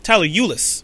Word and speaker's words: Tyler Ulis: Tyler Ellis, probably saying his name wrong. Tyler Ulis.